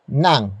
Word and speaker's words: ng: Up next we have nang, which is spelled as N-G.